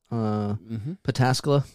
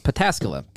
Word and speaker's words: Pataskala: Pataskala. – Pataskala. Pataskala, Ohio, which is basically out, like right outside Columbus.